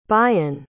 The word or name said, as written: bayan